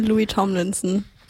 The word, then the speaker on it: Louis Tomlinson: Jetzt haben wir für euch Sunflowers von Louis Tom Linsner. Linson? Wie wird's ausgesprochen? Kann mir jemand helfen? – Sunflowers von Louis Tomlinson.